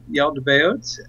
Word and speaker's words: Yaldabaoth: I pronounce it Yaldabaoth.